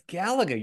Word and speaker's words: Galaga: Galaga? I don't know. – It's Galaga, you freak.